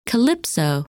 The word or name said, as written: Calypso